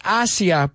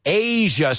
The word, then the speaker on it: Asia: Que espero que no lo pronunciáis Asia. Por favor, no, it's Asia.